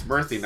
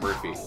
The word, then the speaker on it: Murthy: It's Murthy, not Murphy, but it's, yeah, it's, well, he looks young.